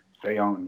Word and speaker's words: Bayonne: Bayonne. – Bayonne, yeah, yep.